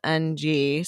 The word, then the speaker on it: Ng: And they hired Kim Ng, which I'm like, her last name is just spelled NG.